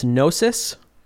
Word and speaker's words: Gnosis: So let's take a listen to, Will, would you pronounce this Gnosis? – Yeah. – G-N-O-S-I-S. Gnosis from Zeno Saga Episode 1.